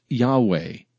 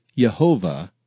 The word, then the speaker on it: Yahweh: Ancient Hebrew did not have vowels, so the exact pronunciation of YHWH is uncertain. The vast majority of Hebrew and Christian scholars believe the name to be pronounced Yahweh, with Jehovah being the second most popular possibility.